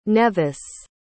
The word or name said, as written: neves